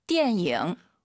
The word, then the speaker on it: 电影: 자, 띠에닝, 띠에닝 절대 안 됩니다. 연음, 니은 발음 들리면 안 돼요. 띠엔잉 들어볼게요. – 电影。